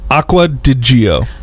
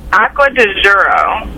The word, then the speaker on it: Acqua di Gio: Acqua di Gio. – Everybody knows the cologne. – Acqua di Gio.